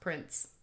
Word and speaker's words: Prince: It's pronounced Prince.